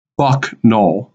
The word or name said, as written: Buck Knoll